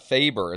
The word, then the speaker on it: Faber: Hey, Dwayne Faber, is that how I say your last name? – It is, yes.